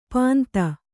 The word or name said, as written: ಪಾಂತ